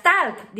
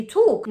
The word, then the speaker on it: talk: No digas talk, di talk.